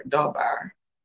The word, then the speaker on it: dabar: We see writers in the Old Testament also using the Hebrew word dabar, which is spelled D-A-B-A-R and pronounced dabar, to refer to the word of God.